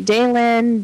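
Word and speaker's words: Dehlin: I think I'm saying his name correctly. Everybody seems to say it differently. DeLynn. – Well, DeLynn is how his family pronounces it.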